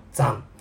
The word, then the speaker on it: 斬: まずはそのザンについてのお話から始めていきたいと思います。ザン。車辺に金と書いて切ると読む漢字のザンですね。